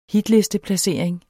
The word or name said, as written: hitlisteplacering